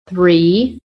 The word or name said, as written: THREE